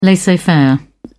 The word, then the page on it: laissez-faire: British English